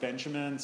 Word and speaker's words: Benjamin: Sometimes pronounced Benjamin, sort of.